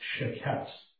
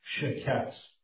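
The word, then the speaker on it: sheketz (שֶׁקֶץ): The first word for abomination is Strong's 8263, and it's pronounced sheketz, S-H-E-Q-E-T-S, sheketz. The second Hebrew word is Strong's 8262, and that's shaketz, S-H-A-Q-A-T-S.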